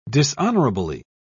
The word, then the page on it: dishonourably: British English